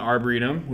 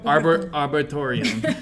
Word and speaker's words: arboretum: He didn't know how to say it. – Yeah. Arboretorium. – I think that's what I said. – That is what he said.